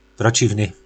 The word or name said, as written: procivny